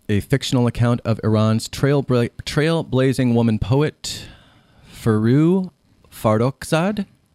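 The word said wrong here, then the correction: Forugh: it's Furuk Farrokhzad. – Farouk. So there's it almost sounds like a K on the end.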